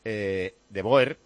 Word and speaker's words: De Boer: De Boer se pronuncia De Boer.